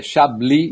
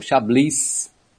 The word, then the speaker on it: Chablis: Chablis e não Chablis, né? O S no final o francês não pronuncia.